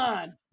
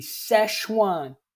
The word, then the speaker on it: Szechuan: Szechuan. – Yeah. And then everyone... – That was terribly pronounced. – Well, I'm not good at pronunciation. – Say it with me. Szechuan.